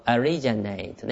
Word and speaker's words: originate: 動詞ですと originate ね。 originate ちょっと読み方が変わりますね。はい、名詞は origin、動詞は originate ね。はい、 originate じゃないんでね、気をつけてください。